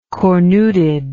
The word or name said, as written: cornuted